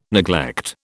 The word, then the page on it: neglect: British English